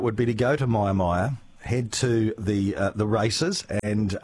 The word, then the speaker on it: Mia Mia: Maya Maya is spelled M-I-A-M-I-A.